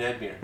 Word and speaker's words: Edmure: You mean Edmure. – No, Edmure. Edmure Tully. Is it Edmure Tully? Yeah, I'm sorry.